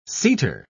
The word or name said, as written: seater